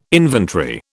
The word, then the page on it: inventory: British English